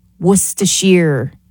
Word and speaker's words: Worcestershire: Worcestershire is how you pronounce it.